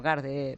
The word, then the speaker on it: Guard: Guard, bueno, Guard, pronunciado en inglés.